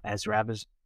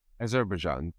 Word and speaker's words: Azerbaijan: The pronunciation is Azerbaijan. – I can't even say it now. – Azerbaijan. – Azerbaijan.